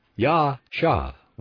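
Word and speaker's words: Yasha (יָשַׁע): Strong's tells us that it comes from the Hebrew word Yasha, pronounced Yasha, Y-A-W-S-H-A-H, Yasha, meaning to save or salvation.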